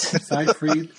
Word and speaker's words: Seyfried: Seyfried. So like going, if you do the German pronunciation, Seyfried. I got the last part right. You pronounced the last E. I'm like going, it's that, you know, EY.